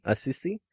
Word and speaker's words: Assisi: Assisi is the much loved national patron saint.